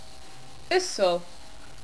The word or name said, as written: usul